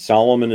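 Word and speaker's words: Salomon: And Solomon is spelled S-A-L-O-M-O-N. I may have the wisdom of Solomon, but I don't have the name.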